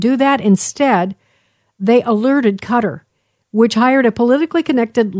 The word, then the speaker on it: Qatar: And so one wouldn't think that they would be like working with Qatar is the way I understand it's pronounced.